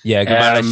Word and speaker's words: Guimarães: Yeah, Guimarães.